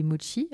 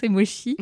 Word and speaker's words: mochi: Et c'est comme ça qu'on va arriver à parler des mochi. Alors, j'ai appris grâce à toi qu'on ne disait pas mochi, parce que moi, je les mange, mais je les prononce mochi. Et donc, c'est mochi.